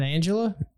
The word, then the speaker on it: Angela: Isn't that Angela? Oh, it's a hard G. It's yeah, it's German.